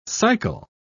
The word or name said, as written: cycle